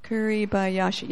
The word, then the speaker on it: Kuribayashi: The general, okay, I'm going to say his name, the Japanese commander of Iwo Jima, Kuribayashi, I think I said it right. Kuribayashi, yes. General Kuribayashi was brilliant.